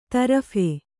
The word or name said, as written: ತರ್ಫಿ